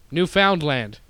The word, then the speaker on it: Newfoundland: But not Lund, land. You got to really emphasize that. Newfoundland. It's literally how it's spelled. Newfoundland. Land. So just say those words and you're good.